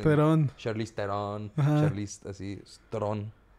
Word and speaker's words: Theron: Se pronuncia Tron. – ¿Se pronuncia Tron? Sí, güey. ¿Como la película? – No, no Tron, pero Tron.